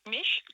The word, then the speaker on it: Меш: Но я гораздо меньше знаю второй коллектив, который, как я понимаю, читается как «Меш», правильно, да? – «Меш», а я там уже не участвую.